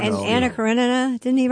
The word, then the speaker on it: Karenina: No, you said it right. It's Karenina. You're right.